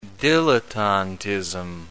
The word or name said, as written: dilettantism